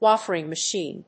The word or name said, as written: wafering machine